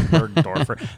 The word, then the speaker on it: burgdorferi: it directly produces antibodies for Borrelia burgdorferi. How did you pronounce that? – Yeah, burgdorferi.